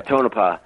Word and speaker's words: Tonopah: And Tonopah, or I hope I'm pronouncing that right, but yeah, Tonopah.